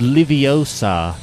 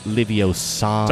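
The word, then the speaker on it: Leviosa: It's Liviosa, not Liviosa. – Sorry. – You got to put the right emphasis on the right syllable.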